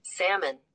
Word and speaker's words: salmon: Oh my God, I can't even say the word. How do you say it? Let me see. – Salmon. – Oh my God, it is salmon. You learn something and Salmon. – everything. – Oh my God. Wait, there, I'm so dumb. There is a silent L.